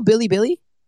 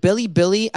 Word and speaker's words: Bilibili: Are you sure you're pronouncing it right? I know you're French or whatever, so are you, you know, maybe throwing a French pronunciation in there? – Bilibili. Bilibili. Bilibili.